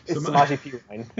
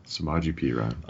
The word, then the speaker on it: Samaje Perine: Samaje Perine. – Samaje Perine.